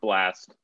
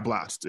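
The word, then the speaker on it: Blxst: Pronounced Blast. – Yeah, Blast.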